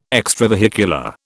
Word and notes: extravehicular: British English

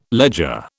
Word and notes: ledger: British English

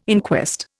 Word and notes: inquest: Indian

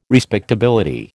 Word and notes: respectability: American English

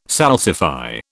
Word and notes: salsify: British English

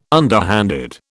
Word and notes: underhanded: British English

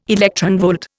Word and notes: electronvolt: Indian

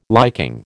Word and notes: liking: American English

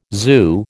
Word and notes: zoo: American English

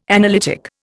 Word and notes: analytic: Indian

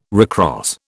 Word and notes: recross: British English

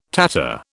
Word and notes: Tatar: British English

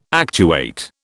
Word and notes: actuate: British English

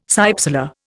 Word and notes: cypsela: Indian

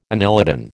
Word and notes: annelidan: American English

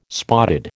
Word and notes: spotted: American English

